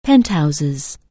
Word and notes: penthouse: anglais

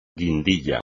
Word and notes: guindilla: espagnol

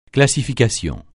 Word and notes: classification: francais

